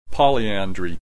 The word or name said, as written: polyandry